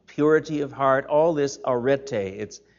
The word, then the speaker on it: arete: This word virtue, the Greek word arete, A-R-E-T-E, arete, the last E is a long E,